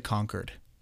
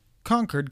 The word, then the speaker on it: Concord: I got a couple DMs that I said that wrong, so I'm gonna say Concord, Concord, California instead of Concord.